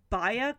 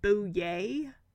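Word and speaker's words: Buyea: It's not Baya, because it's B-U-Y-E-A. It's actually Bouye.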